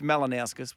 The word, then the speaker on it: Malinauskas: It's Malinauskas. I did say it right.